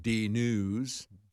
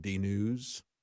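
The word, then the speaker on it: DNyuz: One of the stories from D News, D-N-Y-U-Z, I guess is how you pronounce it, D News, a left-wing rag, if ever there was one, like Daily Beast.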